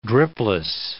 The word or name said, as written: dripless